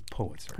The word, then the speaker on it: Pulitzer: Every time somebody says Pulitzer, somebody sends me an email saying you're mispronouncing it. So it's Pulitzer. Pulitzer.